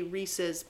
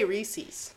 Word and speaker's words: Reese's: They say that it should rhyme with pieces, so it should be Reese's Pieces. – But Pieces came out after Reese's. I say Reese's.